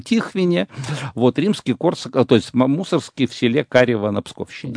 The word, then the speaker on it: Мусоргский: Дело в том, что мы, ну, я буду говорить для привычки, как бы, продолжать Мусоргский, но вообще я хочу, чтобы слушатели знали, вообще-то правильно Мусоргский.